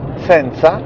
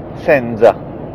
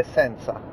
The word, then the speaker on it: senza: In questo caso la pronuncia esatta è senza, come costanza,